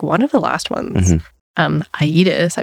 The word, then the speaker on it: Aedes: The new 80s scent. 80s, A-E-D-E-S. – Not that it's a pronunciation podcast, but I do know how to pronounce it. – Is that it? – It's Aedes.